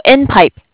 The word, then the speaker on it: IN-pipe: It's called an M-pipe system. – Oh, M-pipe. – How do you spell that? – Oh, N-pipe. I-N oh. – dash pipe. Oh, I thought you were telling me it's M as in Mary pipe.